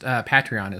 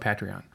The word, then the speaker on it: Patreon: I've heard it pronounced both ways, but I think it sounds more dignified the way I pronounce it. – I understand, but you've got to understand that for a kid from Fresno, I'm going to call it Patreon.